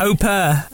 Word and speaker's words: au pair: How do you say it? – Au pair. – That's all right, then I'll let you off.